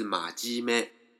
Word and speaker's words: まじめ: 有一个字呢，呃，发音跟这个很类似的，就是在马跟咩中间呢加一个鸡，也就是马鸡咩。